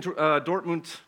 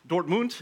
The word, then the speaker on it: Dortmund: And we've been in Dortmund. Dortmund is the German pronunciation, Aussprache, but Dortmund is okay as well.